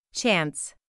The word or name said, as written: chance